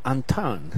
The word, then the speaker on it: Anton: El otro día platicábamos con Jorge Luis Lechuga, quien es cronista de Los Tecos, y le digo, dime la pronunciación bien. Y lo, Anton. – Dice, sí, Anton. Muy como muy francés.